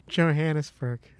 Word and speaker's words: Johannesburg: Can you not pronounce it? – Why can't I say it? Johannesburg. – And there you go. You got it.